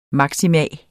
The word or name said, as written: maksimum